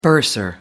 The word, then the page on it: bursar: American English